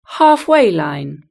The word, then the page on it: halfway line: British English